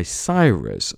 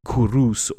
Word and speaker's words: Cyrus: Now, this original kind of collection was actually a vassal kingdom, probably of the Medes, and they were unified by Cyrus, or Kurus, or Sirius, depending on how you pronounce it.